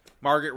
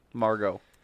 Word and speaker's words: Margot: they didn't have Margot Robbie as a nominee. – Margot. – Say it right, bro. You can't disrespect the Margot.